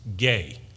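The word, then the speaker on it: gay (γῆ): it's spelled G-E, it's pronounced gay, which refers to a very large region in some cases.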